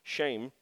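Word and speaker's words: shame (שם): Uh, if you look up the word uh, name in Hebrew, S H E M, is it's actually pronounced shame means honor, authority, and character.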